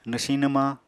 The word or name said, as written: nwsiñ-nwmañ